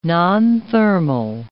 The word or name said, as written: nonthermal